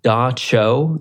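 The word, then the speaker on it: dab tsog: is that they pronounce it Da Cho.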